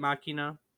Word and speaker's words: Machina: Isn't that how you say it, Machina? – Yeah.